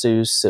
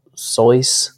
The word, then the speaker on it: Seuss: And then I read a thing where Dr. Seuss, supposedly in an interview, he said it wasn't pronounced Seuss. It was like Sois.